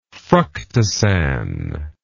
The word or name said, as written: fructosan